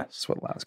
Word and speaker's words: Swiatlowski: So the IA becomes like a soft E. So it's actually just like sweat, like you're perspiring. Swiotlowski. – Mm-hmm. Swiotlowski. – Okay. Yeah. – Swiotlowski.